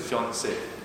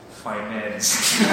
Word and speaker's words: fiancé: So it's supposed to be fiancé. Me at the age, you know why I write? Finance.